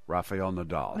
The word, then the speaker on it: Rafael Nadal: Rafael Nadal.